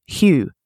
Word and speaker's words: Hygge: But the book I borrowed actually has the pronunciation on the front of the cover. It's the book of Hygge, and it has in brackets Hygge.